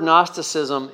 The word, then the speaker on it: Gnosticism: Okay, that's GN. And notice that the word Gnosticism is similar to our word knowledge. Okay, so instead of a silent K, it's a silent G, but you know, K-N-G-N.